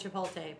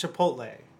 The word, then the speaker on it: Chipotle: That and Chipotle, but... – It's Chipotle. You're pronouncing it wrong.